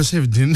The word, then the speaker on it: Safaldin: Je m'appelle Safaldin. – Safaldin, eh ben. – Comme ça, la prononce bien.